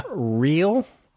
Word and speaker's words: Rhyl: Real? R-H-Y-L? – Oh, yeah. I think you're... – Am I saying it right? – Yeah.